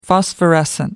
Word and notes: phosphorescent: American English

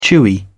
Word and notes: chewy: British English

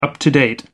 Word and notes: up-to-date: American English

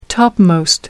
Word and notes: topmost: American English

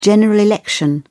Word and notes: general election: British English

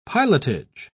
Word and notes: pilotage: British English

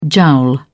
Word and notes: jowl: British English

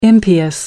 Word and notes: impious: American English

